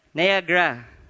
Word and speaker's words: Niagara: In 1853, I think, he was the first one, tightrope artist, who crossed, how do you pronounce that? Nagaraya? Glenda, you're here. Okay. Niagara. Niagara.